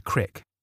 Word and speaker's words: creek: Creek monster. If you live in central Illinois, that is pronounced crick, not creek.